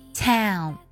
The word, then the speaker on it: town: Town. T-O-W-N. O-W 的发音呢，在美式的发音当中发作 L。起音的时候呢，它更偏向于梅花音。哎，然后加乌。 Town. 这样去读的。